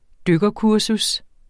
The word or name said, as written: dykkerkursus